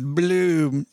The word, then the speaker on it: Blooom: With Bloom, I don't say it right. Say it. – Bloom. – With Bloom, you can simply connect your existing 401k in a few easy steps.